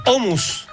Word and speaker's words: hummus: Quisiera referir que el hummus se pronuncia hummus.